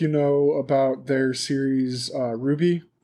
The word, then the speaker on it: RWBY: Ruby. – It's spelled R-W-B-Y. It's a series that Rooster Teeth put out. – Oh, Red, White, Blue, Yellow.